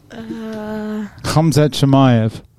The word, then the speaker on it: Khamzat Chimaev: Khamzat Chimaev.